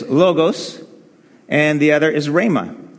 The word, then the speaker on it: rhema: Yep, some of you, how many of you heard of rhema? Rhema, you're supposed to actually say it like you have something stuck in your throat.